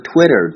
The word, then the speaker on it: Twitter: 另外，大家注意 tweet 中的 e 发音是长音。 Tweet, tweet. 而 Twitter 中的 i 的发音应该是短音。 Twitter, Twitter, 这两个大家发音的时候一定要区分开。